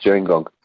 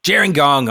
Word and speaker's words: Gerringong: It's actually Gerringong, pronounced Gerringong. – Gerringong.